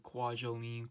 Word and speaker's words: Kwajalein: Kualesian. I might have butchered the name Kwajalein, Kwajalein, but